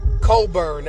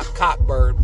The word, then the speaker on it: Cockburn: Coburn, that's how you pronounce that kid's name, not Cockburn.